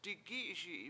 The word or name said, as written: di7gI7iSi7i